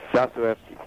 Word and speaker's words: Dostoevsky: Dostoevsky.